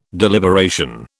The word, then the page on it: deliberation: British English